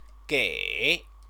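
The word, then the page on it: 给: gĕi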